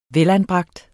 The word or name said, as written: velanbragt